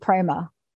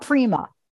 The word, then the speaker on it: prema: I think I pronounced it prema, and not prima. Yeah. Is prema correct? – Prema. Yeah. Prema. – Right. Not prima.